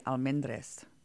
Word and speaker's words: Almond: The real way would be Almendres. – Oh, what? I don't see any S's in your name. – We Americanized it.